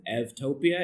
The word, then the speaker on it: evtopia: And so then the second word is evtopia, and it's EU, but with the Greek pronunciation, you can pronounce the U like a V,